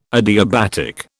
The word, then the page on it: adiabatic: British English